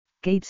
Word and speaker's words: Caitriona: Suena como Kate pero se escribe distinto».